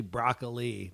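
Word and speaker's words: Brockali: It's actually Broccoli, not Brockle, as I said.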